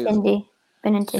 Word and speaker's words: Benintendi: With the Red Sox for Benedina or whatever you say. – Benintendi.